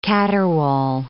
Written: caterwaul

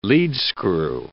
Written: leadscrew

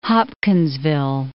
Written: Hopkinsville

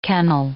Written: kennel

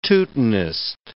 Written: Teutonist

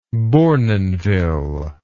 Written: bournonville

